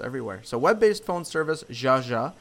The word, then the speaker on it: Yaya: Yaya? – I'm not the right person to answer that. – It's pronounced Jaja.